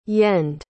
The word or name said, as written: yend